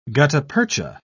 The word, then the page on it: gutta-percha: British English